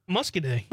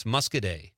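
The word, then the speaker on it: Muscoda: Gary from Madison says, the town in southwest Wisconsin is pronounced Muscadet? – Muscadet. – Okay. – Muscadet, yep. – PJ also says that on the stream. He checks in. It's Muscadet.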